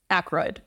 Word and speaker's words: Aykroyd: How do you say that? – Aykroyd. – Dan Aykroyd.